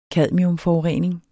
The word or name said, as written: cadmiumforurening